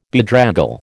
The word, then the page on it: bedraggle: American English